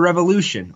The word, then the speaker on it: Revolution: And you had NXT TakeOver Revolution, or R-Evolution, depending on how you say it. I've heard both.